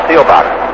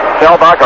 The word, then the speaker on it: Seelbach: Spells his name, S-E-E-L-B-A-C-H, and up until about a month ago, it was pronounced as it was spelled, Stelbach. But Chuck wanted to change, the pronunciation changed anyway. Stelbach, a right-handed batter, he struck out his first time, and he chops one foul off to the left.